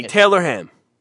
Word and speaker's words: Taylor Ham: Why can you say it right there and not when you say the whole thing? – I don't know what the procedure is. – Say it after me. Taylor Ham.